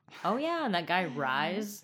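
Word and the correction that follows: Rhys: Reese. What the fuck? In what universe would his name be Rise? – It's spelled R-H. – Oh, yeah, it Y. is actually a weird spelling.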